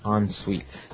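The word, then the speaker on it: en-suite: Hyphen S-U-I-T-E. – So even though it's E-N, we pronounce it like an O-N. – Yep. – Ensuite.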